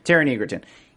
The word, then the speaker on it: Taron Egerton: Taron, is that how you pronounce his name, Marissa? Taron Egerton? I don't know how to say it right. Is that it? Okay. Yeah, Taron Egerton.